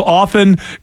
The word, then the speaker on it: often: Pronunciation of often is silent T.